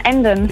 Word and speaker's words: Emden: Aus M, ne? – Emden. – Ja. – Genau. Ja, wir haben es wie ein Local aussprechen wollen und da ist es ja dann nur noch Genau, richtig.